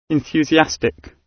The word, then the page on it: enthusiastic: British English